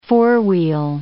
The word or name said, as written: four-wheel